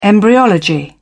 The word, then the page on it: embryology: British English